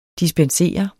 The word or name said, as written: dispenser